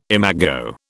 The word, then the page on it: imago: British English